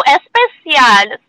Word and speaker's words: Especial: And but when you pronounce especial, it sounds like the Spanish version of the word special, which is especial.